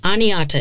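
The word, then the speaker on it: Oneota: Oneata people, which to me looks like one O-T-A, O-N-E-O-T-A, but I looked it up, and Oneata is how they pronounced it, lived in that area for 8,500 years.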